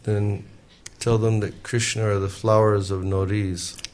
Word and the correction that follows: Navruz: How do you say? Navruz. Navruz.